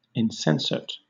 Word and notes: insensate: British English

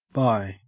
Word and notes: bye: Australian English

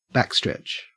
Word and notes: backstretch: Australian English

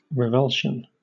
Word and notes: revulsion: British English

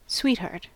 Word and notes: sweetheart: American English

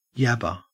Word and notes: yabber: Australian English

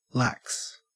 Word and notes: lax: Australian English

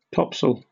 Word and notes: topsail: British English

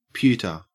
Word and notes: pewter: Australian English

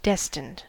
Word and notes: destined: American English

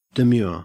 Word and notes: demure: Australian English

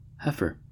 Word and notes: heifer: American English

